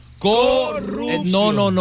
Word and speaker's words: corrupción: Bien, miren, corrupción es una palabra aguda que lleva acento en la O y tiene doble R. Repitan conmigo. – ¡Corrupción! – No. Primero. Repitan conmigo. – ¡Corrupción!